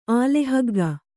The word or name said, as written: ಆಲೆಹಗ್ಗ